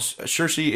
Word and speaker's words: Saoirse: Basically, the story here is about two children, Ben and Saoirse, I believe is how you pronounce it. – Saoirse? – Like Saoirse Ronan. – Saoirse Ronan? – Yeah, the actress whose name is spelled with no H in it whatsoever.